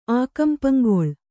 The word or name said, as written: ಆಕಂಪಂಗೊಳ್